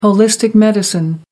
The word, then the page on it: holistic medicine: American English